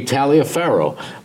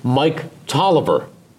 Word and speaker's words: Taliaferro: Okay, if you were to pronounce it just on the spelling, it would be Taliaferro, but he pronounced it Mike Tolliver.